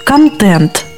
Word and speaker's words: контент: Поэтому правильно говорить «контент» твердо, «патент» твердо, «тезис», но «шинель» – термин.